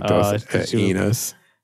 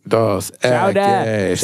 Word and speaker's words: Dos Equis: Like Dos Enos. – They didn't know how to say it.